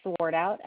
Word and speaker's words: Swartout: It is Swartout, S-W-A-R-T-O-U-T, and it is 37115.